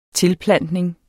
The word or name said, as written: tilplantning